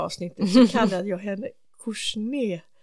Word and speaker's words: Kouchner: Kouchner ska uttalas som Robert.